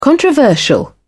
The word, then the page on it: controversial: British English